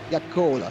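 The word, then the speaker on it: Jaakkola: Oh, Jakula. We're using a soft J, are we? Jakula. – Okay. I would say so, yeah.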